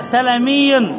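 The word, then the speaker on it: سَلَمي: قال الخطأ سلمي، الخطأ سلمي. قل س إله إلا الله. أتعجب كيف ينطقها، يعني كيف يعتاد الخطأ صاحبه. سلمي سلمي سلمي. قال في النسب إلى بني سلمة، الصواب ماذا؟ سلمي سلمي. لماذا بفتح اللام؟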